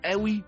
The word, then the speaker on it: قوي: اهي كلمة زي كلمة قوي بالهمزة مش بالقاف، كان القدماء المصريين بينطقوا بيها بنفس النطق ده، قوي يعني بعيد.